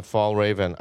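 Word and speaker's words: Fjällräven: How do you say Fall? Is it Fall Raven? – Yeah, Fall Raven. I think they're Swedish.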